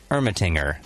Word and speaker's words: Ermatinger: And we have the owner slash head of fermentation, Mitch Ermatinger.